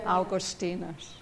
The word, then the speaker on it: Augustinus: Sorry, English pronunciation. He spoke Latin. He wrote Latin. Augustinus. So then, when we break into the infinity of God, we escape from all these borderlines that confine us now.